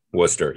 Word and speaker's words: Worcester: How do you pronounce it? Worcester. – Oh, Worcester. Yes.